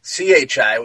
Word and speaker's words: qi: Life force's energy is commonly referred to as QI, pronounced CHI, which is chai, like in your potato chips and potatoes.